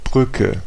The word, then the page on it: Brücke: [ˈbʀʏ.kə]; /ˈbrʏ.kə/